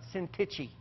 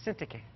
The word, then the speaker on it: Syntyche: In the church in Philippi, there were two women, Euodia and Syntyche, not Syntyche, all right? We've said that wrong forever. It just drives me crazy every time I hear something. It's Syntyche.